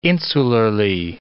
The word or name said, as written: insularly